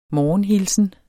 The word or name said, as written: morgenhilsen